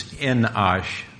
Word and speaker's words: Enosh: It's Enosh, the way it's pronounced.